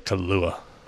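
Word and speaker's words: Kahlúa: I don't know how to say it properly. Kahlua? Okay. Kahlua? – Kahlua.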